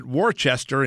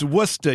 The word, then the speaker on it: Worcester: So like Elizabeth Warren, I guess we were mispronouncing it because we kept asking the people on the train, how come they're not stopping at Worcester? And finally a lady goes, it's Worcester. You ain't from here, are you? – Hey, Howie.